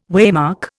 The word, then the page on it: waymark: Indian